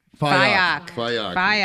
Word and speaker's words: Fikok: Fitness expert, Tori Fikok. Am I saying it right? – Fayok. – All right. We'll take out the C.